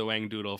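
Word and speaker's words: Whangdoodle: Well, I'm going to need to remember the Wang Doodle for future reference, just anytime you need. – W-H-A-N-G Doodle. – Okay. – There's a silent H. – Careful, there's going to be a Wang Doodle around the corner.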